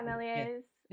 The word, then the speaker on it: Méliès: The Méliès, am I saying that right? Méliès. – Yeah, Méliès. – Yeah, Méliès.